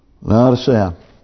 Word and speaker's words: Laodicea: And did I pronounce that correct? I remember I'd been pronouncing that incorrectly for years. Yep. Laodicea. Now, I want to go back and get that second church, which is the church at Pergamos.